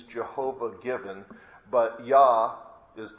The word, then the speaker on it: Yah: If you have a King James Bible, it's spelled J-A-H, but if the pronunciation of it is just two letters, Yod and He in Hebrew, Yah.